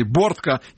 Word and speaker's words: Бортко: Вот мы начали с заявления, поводом для избрания данной темы, заявление не кого-нибудь, а Владимира Бортко, или Бортко, если точно ударение.